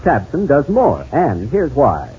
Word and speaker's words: Tabcin: But have you heard about Tabsin? That's spelled T-A-B-C-I-N.